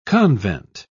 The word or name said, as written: convent